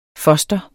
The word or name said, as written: foster